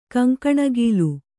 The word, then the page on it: ಕೆಂಗಣಿಗಲು: keŋgaṇigalu